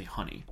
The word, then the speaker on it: Hunie: See, I always pronounced it when I first saw it, or even now, I pronounce it Hunie. Because it's like hue related to color, is what I thought. But the name of it is obviously Honey.